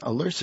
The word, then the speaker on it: alerce: That's spelled A-L-E-R-C-E, alerce.